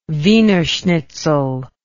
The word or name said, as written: Wiener schnitzel